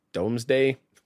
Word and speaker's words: Domesday: It's spelled D-O-M-E-S, Domesday, basically, and both of these interviews will pop up.